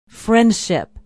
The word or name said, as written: Friendship